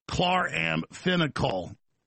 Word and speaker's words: chloramphenicol: Spelled K-L-A-R-A-M-F-E-N, or pronounced, spelled C-H-L-O-R-A-M-P-H-E-N-I-C-O-L, pronounced chloramphenicol, chloramphenicol. Unbelievable that this is going on.